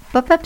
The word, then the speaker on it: Pop-up: Oui, Pop-up, c'est mon nom. On prononce pop, comme dans pop music ou population mondiale, et pup, comme dans peuplier ou un peu plus à l'ouest.